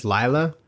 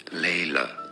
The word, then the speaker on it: Layla: Generally in English, it would be said as... Layla. – Let's go. – Layla. Some might say it more as Layla. – That's the Layla. Same thing. – Very few would pronounce it unless it's a different language as Lila. Generally speaking, Layla. And now you know.